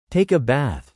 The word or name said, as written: take a bath